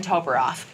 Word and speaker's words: Toboroff: She's the Republican candidate for City Council District 1, Jacqueline Toboroff.